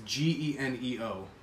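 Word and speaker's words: Geneo: It's not like G-E-N-O. It's G-E-N-E-O. Oh, it's literally like Gene-O.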